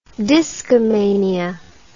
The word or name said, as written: discomania